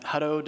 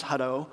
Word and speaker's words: Hutto: I just thought it was Hutto, you know. But people have been pronouncing it Hutto as well, really enunciating the T's. And they're like, well, it's a double T, Hutto. And I'm like, well, that's a lot of syllables that us Texans, we like to condense those as much as possible. Plus, we don't say butter, you know, we say, we change the T's to D's and we say butter.